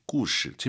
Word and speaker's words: 故事: 그럴 때는 이 시가 사성입니다. 실구 이렇게 발음을 하시고요. 여기서는 경성입니다. 故事. 그래요.